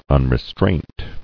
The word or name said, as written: Unrestraint